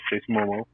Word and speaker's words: Jason Momoa: I mean, they can still do it with, Gal Gadot and Jason Momoa. Did I say that right? – Yeah. – Yeah. – Yeah, Jason Momoa.